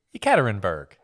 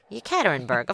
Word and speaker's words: Yekaterinburg: Of Yekaterin. Nah. Now I forget how to say it. – Yekaterinburg. – Yekaterinburg, of course.